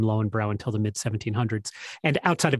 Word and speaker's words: Löwenbräu: And of course, you know, it's pronounced Löwenbräu in German.